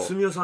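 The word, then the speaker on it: 純男: すみおさんの純粋の純と書いてすみおと読むんですね。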